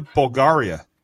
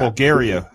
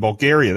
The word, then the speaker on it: Bulgaria: Bulgaria. – Oh, damn. Crap. – Bulgaria. I said that wrong the first time. Bulgaria, that's it.